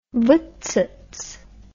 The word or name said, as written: voetstoots